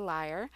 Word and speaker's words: lyre: Mine is the lyre, which that is how it's pronounced.